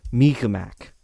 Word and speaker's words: Mi'kmaq: I gave it 5 stars out of 5, and I believe the pronunciation is closer to Mi'kmaq.